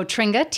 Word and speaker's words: Tringa: I've got my Facebook page and all my social media, Instagram at Tringa Rixepi, and Twitter, and I post about all my shows and everything. So you better spell that last name. – So Tringa, T-R-I-N-G-A, Rixepi, R-E-X-H-E-P-I.